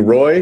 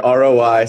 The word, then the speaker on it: ROI: A lot of people say Kenji Roy, but it is in fact ROI, standing for Return on Investment.